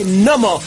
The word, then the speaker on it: gnomo: Si tú fueras un gnomo, sabrías que la G no se pronuncia. Se dice gnomo, ¿eh? Es gnomo.